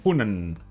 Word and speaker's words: Funen: La tercera ciudad más grande y la capital de la isla de Funen, mm. – que en realidad se pronuncia Fun.